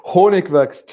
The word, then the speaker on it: Hornigwachs: Hornigwachs, technically. It's German.